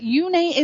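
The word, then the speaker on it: Uné: So we're going to talk about Yune. Yune, Yune, Yune. It's spelled U-N-E with a little slash over the E, and Yune is he is a self-taught singer-songwriter, which I think is absolutely incredible, amazing.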